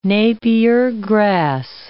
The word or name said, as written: napier grass